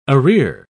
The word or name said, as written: arrear